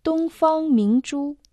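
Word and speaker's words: Тунгфанг Мингчу (东方明珠): По-китайски название телебашни звучит как «Тунгфанг Мингчу». «Тунг» произносится в первом доне, означает «восток», «восточный». «Фанг» – первый дон, значит «страна».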